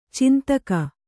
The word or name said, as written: ಛಂದಕ